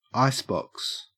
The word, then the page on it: icebox: Australian English